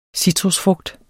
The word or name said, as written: citrusfrugt